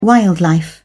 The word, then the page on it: wildlife: British English